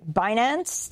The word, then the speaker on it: Binance: Binance.